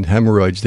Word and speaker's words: hemorrhoids: I think you put an extra syllable in hemorrhoids there when you just said that.